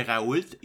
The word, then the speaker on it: Raoult: Juste pour rappeler à nos auditeurs, Didier Raoult, d'après ce que je vois, il faut, prononcer le T.